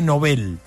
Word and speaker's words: Nobel: Que dicen que se dice Nobel. – Sí, ¿okay? Alfred Nobel. En realidad. – Dicen que se dice así, que la Academia Española dice que no digamos Nobel, que digamos Nobel.